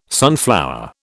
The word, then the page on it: sunflower: British English